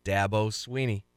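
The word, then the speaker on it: Dabo Swinney: Remember Dabo Sweeney? Dabo Sweeney. That's how you pronounce it.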